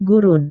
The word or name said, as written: gurun